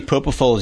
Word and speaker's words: propofol: Yeah, a half hour, and typically it's propofol. Am I saying that properly? – Yes. Usually propofol is used, and that puts you right out.